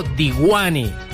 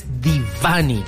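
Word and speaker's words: Deewane: Y la pronuncian como Disco Diwani. – Diwani. – Es Divani. – Divani. – Exactamente. Sí. Disco Divani.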